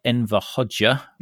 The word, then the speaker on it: Enver Hoxha: and was ruled by Enver Hoxha, which I think is the correct pronunciation, for about four decades, and he died in 1985.